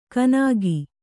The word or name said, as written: ಕನಾಗಿ